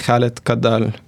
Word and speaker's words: Khaled: Same name for Twitter, Facebook, and my website, Khaled Kadal. – Khaled is spelled K-H-A-L-E-D-K-A-D-D-A-L.